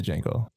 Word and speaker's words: Django: But that's going to be my favorite movie of the year as well, is the Django. – The D is silent.